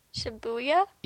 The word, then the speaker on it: Shibuya: Showing off Shibuya in Seventh Dragon 2020. – Oh, that's so, how you say it. I didn't know how to say it, so I just skipped it. – What? – Shibuya? – Yeah, Shibuya.